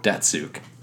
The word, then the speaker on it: Datsyuk: Datsyuk.